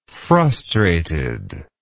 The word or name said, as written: frustrated